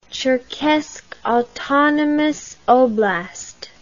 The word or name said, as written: Cherkess Autonomous Oblast